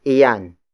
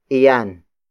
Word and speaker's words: iyan: Masustansya is spelled as M-A-S-U-S-T-A-N-S-I-Y-A. It means nutritious. And then it is followed by iyan. Iyan. Iyan. Iyan is spelled as I-Y-A-N.